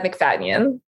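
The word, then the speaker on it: Macfadyen: McFadyen? How do you say it, McFadyen. – McFadyen.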